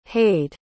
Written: hayed

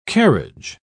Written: carriage

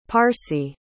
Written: parcae

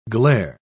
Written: glair

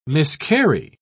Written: miscarry

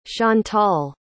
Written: chantalle

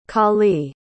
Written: callee

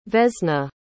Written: vesna

